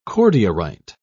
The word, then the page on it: cordierite: British English